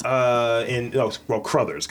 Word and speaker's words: Crothers: So Scatman Carruthers. – Yes. – Crothers, because it's not Carruthers.